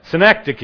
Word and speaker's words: synecdoche: The actual word, the expression is called a synecdoche, if I've pronounced that right, Stacy, is that right? Synecdoche? Okay, whatever. That's whatever she said. Synecdoche. Is that right? I said it right.